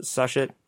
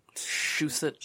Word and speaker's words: Shusett: Dan O'Bannon and Ronald Sushet. – Shusett.